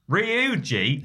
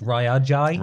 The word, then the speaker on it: Ryuji: So I'm sort of talking like, the best thing about Ryuji is... – It's actually pronounced Ryuji.